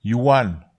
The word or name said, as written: hiuwan